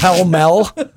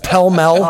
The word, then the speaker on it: Pall Mall: Did you just have some Sanka and a Pall Mall there? – I believe it's Pell Mell. It's pronounced Pell Mell.